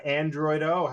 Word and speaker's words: Androidoh: My guest today is tattoo artist from the Bay Area, Androidoh.